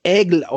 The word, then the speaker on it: Aigle: Also, es wird Aigle ausgesprochen, Aigle geschrieben, Aigle aus Frankreich.